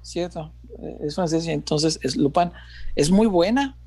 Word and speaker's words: Lupin: Eh, de las recientes acabo de ver, se pronuncia Lupin, ¿será? Lupin. – no está en Netflix. Sí. Lupin. – Ah, es, francés, cierto, Lupin.